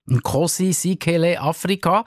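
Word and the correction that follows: Sikelel': Spricht man das richtig so aus? Sikelele Afrika, glaube ich, ist ganz genau.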